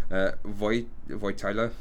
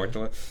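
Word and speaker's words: Wojtyła: It's Karol Józef, now pronunciations is Polish, Wojtyla. – Oh, okay. – Wojtyla.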